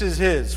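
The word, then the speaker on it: Ish (איש): And it's derived from the Hebrew word for man, which is Ish. So Ish is man, Isha is woman.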